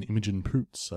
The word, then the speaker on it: Imogen Poots: Yeah, Vivarium, starring Jesse Eisenberg and Imogen Poots, I believe is her name. – That's a great name. – Yeah. – Wouldn't you change that, though? – Oh, no, I think that's how it's pronounced.